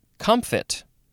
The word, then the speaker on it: comfit: Anyway, let's move on to comfit. Yeah, there's a, maybe a little bit of a P sound in there. Comfit. At least that's what it's saying in the pronunciation guide. It is just C-O-M-F-I-T,